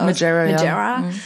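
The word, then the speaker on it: Margera: Und da gab's auch ganz viel so Gossip-Aufruhr, weil Bam Majera, ne? Spricht man das aus? – Majera.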